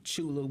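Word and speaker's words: Tchula: That's Chula with a silent T. So it's T-C-H-U-L-A.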